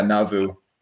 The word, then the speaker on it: Nauvoo: and then a magus in Nauvoo. Am I saying that right? – That's how I say it anyway. That's how most Utahns say it. I don't know what the Hebrew pronunciation was. Yeah, Nauvoo.